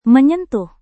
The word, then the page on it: menyentuh: Indonesian